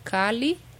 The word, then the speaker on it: chalice: And the other one is chalice. Chalice. But it's the same. – Chalice or chalice. – Yeah, but you pronounce exactly the same, chalice.